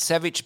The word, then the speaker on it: Savic: Now, it's Savic, but there's no H at the end of how you spell it. – There's no H on it. No. – You're an S-A-V-I-C.